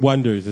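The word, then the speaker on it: Oneders: He just built it up, and then he found the Oneaters. – And the Wonders, it's pronounced.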